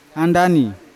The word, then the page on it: andani: Yawa; Unat